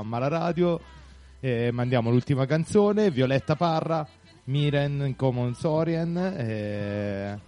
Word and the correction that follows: Cómo sonríen: scusate la pessima pronuncia latinoamericana, non è proprio il mio forte. – Como sonrien.